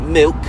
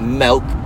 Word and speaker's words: milk: It's pronounced milk, not melk, bruv.